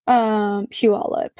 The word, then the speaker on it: Puyallup: She was known to be in either the Federal Way area, Seattle, or Puyallup. Puyallup.